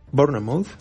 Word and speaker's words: Bournemouth: Bournemouth.